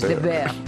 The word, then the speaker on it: The Bear: Lo he dicho bien antes, The Bear.